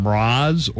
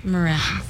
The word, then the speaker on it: Mraz: And also, I didn't know if you pronounce his name Mraz or what. – Mraz.